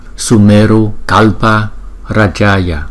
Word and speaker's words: Sumeru Kalpa Rajaya: Tu Di Sơn Vương Phật chúng ta phát âm là Sumeru Kalpa Rajaya.